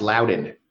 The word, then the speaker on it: Loudoun: It's pronounced Loudoun. Loudoun. Uh, Loudoun. So if you know me, and it's very easy to remember how to pronounce because I actually am quite loud.